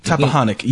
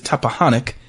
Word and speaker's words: Tappahannock: Tappahannock. You said I was saying it wrong. I kept saying the M. It is Tappahannock, Virginia, and 2,000 people.